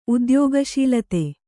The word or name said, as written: ಉದ್ಯೋಗಶೀಲತೆ